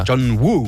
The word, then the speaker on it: John Woo: Come si pronuncia? – John Woo. – Senza ispirare. – Woo. – John Woo. Woo.